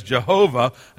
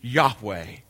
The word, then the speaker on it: Yahweh: We used to be told to pronounce it as Jehovah. And now we're told, probably, I think it's probably right, the best pronunciation would be Yahweh.